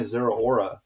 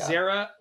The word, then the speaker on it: Zeraora: And then I got to get my Zeraora, which I'm pretty sure I'm not even saying it correctly. – It's Zeraora. Zeraora.